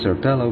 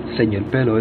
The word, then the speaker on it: Señor Pelo: Some people call it Sir Pelo, but since he is of Spanish origin, then Señor Pelo is the correct pronunciation.